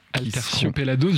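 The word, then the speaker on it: Aterciopelados: Atercio Pelados, ouais. – Si je prononce bien. – Atercio Pelados, je crois, tout à fait. Ouais, je pense que tu prononces bien.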